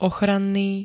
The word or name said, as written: ochranný